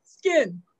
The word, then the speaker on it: Skiin: So Skin, spelled S-K-I-I-N, mispronounced sometimes as Skin. That's an inside joke, but Skin.